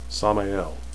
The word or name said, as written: sammael